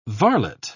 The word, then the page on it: varlet: British English